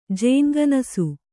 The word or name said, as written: ಜೇನ್ಗನಸು